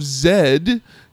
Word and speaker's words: Z: The true life drama pick of the week is called The Lost City of Zed. Not Z, because it's a British.